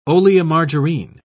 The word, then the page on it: oleomargarine: British English